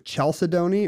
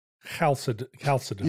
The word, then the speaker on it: chalcedony: How do you say the mineral, like, is it chalcedony or chalcedony? – Chalcedony. – You say like Hanukkah? – Chalcedony. – I really love this chalcedony. It is chalcedony.